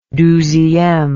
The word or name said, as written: douzieme